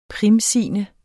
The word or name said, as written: primsigne